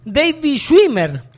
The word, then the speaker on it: David Schwimmer: El nombre real es David Schwimmer. – Sí, sí. – Así se pronuncia.